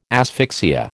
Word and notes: asphyxia: American English

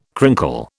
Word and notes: crinkle: British English

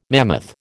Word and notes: mammoth: American English

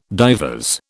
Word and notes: divers: British English

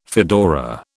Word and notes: fedora: British English

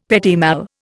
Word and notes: petit mal: Indian

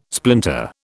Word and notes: splinter: British English

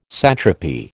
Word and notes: satrapy: American English

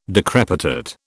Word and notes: decrepitate: British English

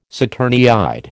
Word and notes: saturniid: American English

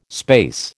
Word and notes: space: American English

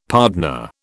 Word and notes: pardner: British English

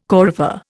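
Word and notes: coryphée: Indian

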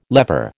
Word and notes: lever: American English

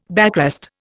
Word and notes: backrest: Indian

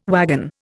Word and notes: wagon: Indian

